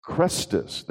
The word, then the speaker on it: Christus: There was such a hubbub that was happening in the Jewish community because of Christus, that's how his name is pronounced,